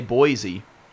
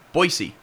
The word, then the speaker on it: Boise: You know that's how they say it there, right? – Provo. – They don't say Boise. – Boise? – Boise.